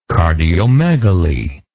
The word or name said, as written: cardiomegaly